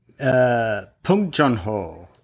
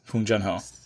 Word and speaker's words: Bong Joon-ho: Bong Joon-ho. – Yes, Bong Joon-ho.